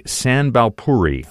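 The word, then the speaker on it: Sambalpuri: Well, if it were me, I would say Sanbalpuri. – Fantastic. Good job.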